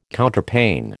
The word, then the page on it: Counterpane: American English